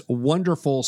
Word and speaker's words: WonderFL: I think I'm saying that right, but we'll clear that up. And for our listeners, that's WonderFL spelled WONDERFL. – That's right. – With the FL representing Florida.